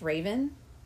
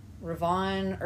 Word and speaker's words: Revan: So, completely side note, but Darth, R-E-V-A-N, is not pronounced Raven. – Oh. – I thought it was, but like all the nerds, correct me, always say Ravon or something like that. I don't know. I've heard different pronunciations, but none of them are Raven.